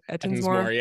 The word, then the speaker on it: Ettinsmore: Ettinsmore, did I say that right? – Ettinsmore, yeah.